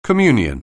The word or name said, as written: communion